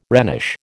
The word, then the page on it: Rhenish: American English